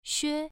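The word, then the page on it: xue: tone 1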